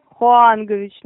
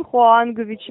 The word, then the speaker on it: Хуангович: Девушка, вы немножко неправильно произнесли мое отчество, не Хуаганович. По-вашему, если следовать вашей логике, моего папа зовут Хуаган. – Слушайте, я не знаю, как указано в анкете, так и произношу, хорошо, как правильно. – Давайте я вам подскажу, Хуангович.